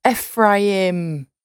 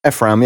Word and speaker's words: Ephraim: Ephraim. – Ephraim, yeah, Ephraim.